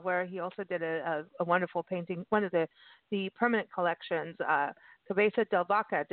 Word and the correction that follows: Cabeza de Vaca: Cabeza de Vaca.